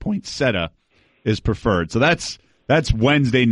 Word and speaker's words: Poinsettia: And we actually called a year ago to ask him if it was Poinsettia or Poinsettia. And it's Poinsettia.